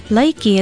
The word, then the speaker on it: laiki: Laiki is spelled L-A-I-K-I and pronounced laiki.